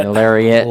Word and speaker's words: Lariat: So back to my midsize, it's got to be the Ranger, the I can't pronounce it, the Lorat or the Lorat. – Lariat version.